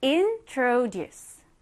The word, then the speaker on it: introduce: 먼저, I am, in, tro, tro. 그리고 duce는 juice. 합쳐볼까요? introduce. introduce.